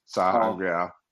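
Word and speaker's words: sa'angreal: Sangreal. Saying Angreal, because I could never say it right. Saw Angreal. Thank you.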